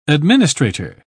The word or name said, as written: administrator